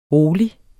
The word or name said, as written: rolig